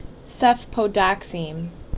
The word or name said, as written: Cefpodoxime